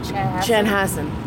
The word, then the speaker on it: Chanhassen: Chanhassen, just so that she could say she was Prince's neighbor back in 2007.